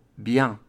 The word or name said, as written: bien